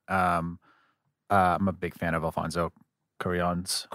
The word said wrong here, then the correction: Cuarón: Cuarón. – Am I saying that wrong? – There's no I.